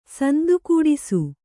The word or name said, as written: ಸಂದುಗೂಡಿಸು